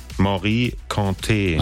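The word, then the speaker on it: Mory Kanté: Wie wird's ausgesprochen? – Morikante. – Ach so, guck mal, das war also falsch.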